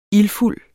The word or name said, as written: ildfuld